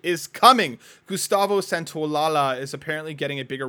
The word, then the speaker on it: Santaolalla: Just Santaolalla.